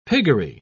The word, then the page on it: piggery: British English